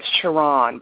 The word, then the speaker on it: Sharrarne: And it's Sharan, by the way. I know I have lots of letters in my name, so I can understand.